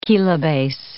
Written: kilobase

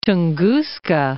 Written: Tunguska

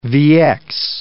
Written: VX

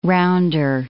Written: rounder

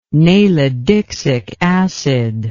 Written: nalidixic acid

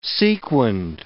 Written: sequinned

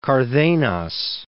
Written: Cardenas

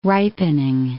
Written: ripening

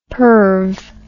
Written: perve

